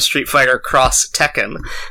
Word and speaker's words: Street Fighter X Tekken: if you see an X in Japanese, like it's usually meant to indicate, it's usually pronounced cross, meant to indicate like versus almost, or like a crossover, like the video game Street Fighter Cross Tekken is spelled Street Fighter X Tekken, or Project X Zone, which is pronounced Project Cross Zone.